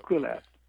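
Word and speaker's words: Kuelap: And because you need the whole day practically to get up to Kulep, K-U-E-L-A-P. I don't speak Incan, and I don't say it correctly, but Kulep, they said Kulep.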